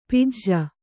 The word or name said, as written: ಪಾಜೆ